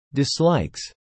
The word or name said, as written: dislikes